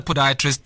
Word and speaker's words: podiatrist: Yeah, let's talk about what is a podiatrist. Am I even saying it correctly? – No, you're saying it perfectly. – Oh, okay. What is a podiatrist,